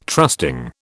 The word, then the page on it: trusting: British English